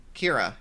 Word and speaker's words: Kira: Kira, yeah. K-I-R-A. That's how you pronounce it, right? – Yeah.